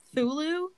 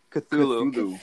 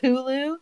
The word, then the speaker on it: Cthulhu: First of all, while we're watching this, Sid did say, it's, how do you even pronounce that? Thulu? – Cthulhu. – Cthulhu. He said it's Cthulhu, and I thought you knew.